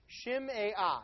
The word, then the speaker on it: Shimei: Yes, that's how you pronounce it. Shimei. Three syllables.